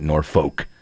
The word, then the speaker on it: Norfolk: That's how you say it, Norfolk.